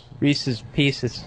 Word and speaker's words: Reese's Pieces: Reese's Pieces.